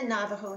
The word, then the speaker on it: Navajo: It's N-A-V-A-J-O, but the J is pronounced as an H, Navajo.